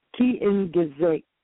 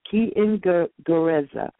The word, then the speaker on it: Kiingereza: But because I have my book in front of me, because every sound in Kiswahili is just like English, I looked at the word and I pronounced what I saw. It's Kiingereza. Kiingereza.